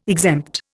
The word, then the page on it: exempt: Indian